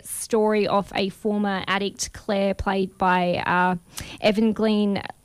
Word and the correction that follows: Evangeline Lilly: I can never say her name properly. – Evangeline Lilly.